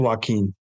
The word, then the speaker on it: Joaquin: is Joakim Niemann. Yeah. – He's a superstar. – Is it Joakim or Joaquin? – Joaquin. Yes. I did that on purpose.